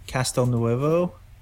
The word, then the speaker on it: Castelnuovo: Castelnuovo, is that? I think that's how you say his name. – Yep.